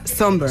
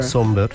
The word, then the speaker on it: Sombr: Pues mira, yo busqué cómo se pronunciaba por si acaso, pero efectivamente se pronuncia Somber. – Somber, sí. – Somber. Exacto. – Y se escribe S-O-M-B-R, por si la gente lo quiere buscar.